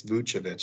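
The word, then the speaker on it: Vučević: I've been pronouncing it wrong. I apologize to all the listeners out there. I just looked up today. It's Vucevic.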